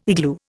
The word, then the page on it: igloo: Indian